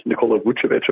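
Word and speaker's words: Nikola Vučević: His name is pronounced Nikola Vucevic, apparently.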